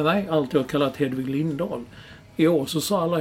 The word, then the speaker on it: Lindahl: Och enligt vår vän Olof Lund så påstår han att det var hon själv som har sagt att hennes namn ska från och med nu uttalas Lindahl.